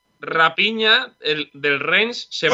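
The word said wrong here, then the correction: Raphinha: Sí, Rafiña. Se, eh. – se, escribe PH, se pronuncia Rafiña.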